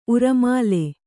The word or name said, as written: ಉರಮಾಲೆ